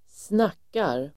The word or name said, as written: snackar